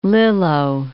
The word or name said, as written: Lillo